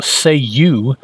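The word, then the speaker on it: Ceiu: and using the special Say You, which is what, C-E-I-U, I think, and it's pronounced Say-You, he binds the Thunderbolt to himself, even though it is an Earth 2 Johnny Thunder that normally has it.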